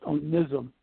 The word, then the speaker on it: Onesim: The first name, it's going to be like a hard I. It's pronounced Onesim.